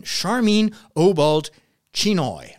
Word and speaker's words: Sharmeen Obaid-Chinoy: and it came from none other than Charmaine Obald-Chinoy. I hope that's how you pronounce her name.